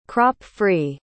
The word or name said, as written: crop-free